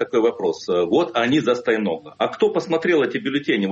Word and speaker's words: Стояноглу: Ну вот вам придется отвечать сразу за две позиции тех, кто живет в Москве и голосует за генерального прокурора бывшего Александра Стояноглу и за тех, кто... – Стояногла. – Стояногла все-таки, да, простите, пожалуйста, Стояногла, буду правильно говорить.